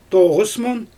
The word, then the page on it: toɣɯs mun: Xyzyl